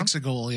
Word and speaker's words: Exegol: They said, allegedly, the Sith planet of, I don't know if I'm going to say this right, Exegol. – Uh-huh. Exegol, yeah.